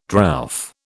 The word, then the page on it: drouth: British English